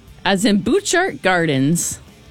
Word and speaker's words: Butchart: A-R-T. – Oh, you got really close. – So close. It's like it's got a silent T or something. – Yeah, B-U-T-C-H-A-R-T.